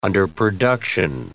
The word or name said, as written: underproduction